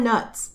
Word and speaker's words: Knuts: And I want to point out that per Pottermore, it is pronounced knuts. Not nuts, with the K being silent. It is knuts.